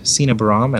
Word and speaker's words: Sina Baram: It's my first name and my last name, at Sina Baram, and I'll spell that.